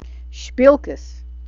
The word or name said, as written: shpilkes